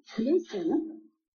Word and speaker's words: Knudsen: Our speaker tonight is Knudsen. Knudsen, the K is pronounced, the D is not.